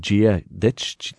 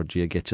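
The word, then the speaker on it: Dia duit: Falch is a big one, F-A-I-L-T-E. Falch means welcome. – Falch. – You could say two words to say hello. You could say Gia Ditch, D-I-A, and then D-U-I-T. Now, Gia, D-I-A is the word for God, and Gia Ditch or Gia Gitch, as some people pronounce it, means God be on you.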